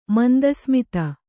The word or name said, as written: ಮಂದಸ್ಮಿತೆ